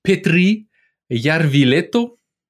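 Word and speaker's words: Petri Järvilehto: Puis là, ce qui arrive, c'est que dans cette gang-là, il y a un gars qui s'appelle Petri Yarvileto. – C'est ça, comme ça que ça se prononce, oui.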